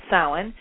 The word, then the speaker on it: Samhain: So from Halloween to, or Samhain, Samhain is what it looks like it's spelled, but it's pronounced Samhain.